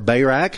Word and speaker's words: Barak: You have Gideon, you have Barak, you have or Barak, however you want to pronounce the man's name.